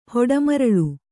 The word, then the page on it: ಹೊಡೆಮರಳ್: hoḍe maraḷ